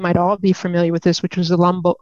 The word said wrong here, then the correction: Humboldt: Humboldt, um, Humboldt bus tragedy.